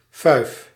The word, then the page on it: fest: /fɛst/